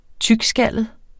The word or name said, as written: tykskallet